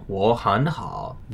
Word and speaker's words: 我很好: Since 我, 很, and 好 are all in the third tone, the first two of those would be pronounced as second tones in 我很好.